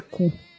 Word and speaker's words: Koo: É, e tem um jogador coreano que é Ku. K-O-O. – Olha aí. – E aí, mas a pronúncia é Ku.